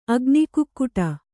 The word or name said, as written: ಅಗ್ನಿಕುಕ್ಕುಟ